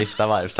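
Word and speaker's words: Vivstavarv: Och utan bekräftelse säger att han har ägt en fiskebåt med källare värd miljoner kronor på exklusiva, exklusiva Viftstavars båtklubb i Timrå. Samt en lägenhet i Skorped. – Är det så man uttalar det? Vifstavarvs, tack.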